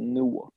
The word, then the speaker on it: Newark: You got to say it like you're from Jersey. The Many Saints of Newark.